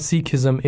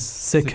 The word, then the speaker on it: Sikhism: um, so anyway, so it's not Sikhism; it is Sikhism. Sikhism. Yeah, mm-hmm. um, even though you spell it and it may sound like it's C S I, Sikhism, mm-hmm.